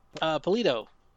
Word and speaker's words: Polito: Polito. – Polito. So it's just the way it's spelled.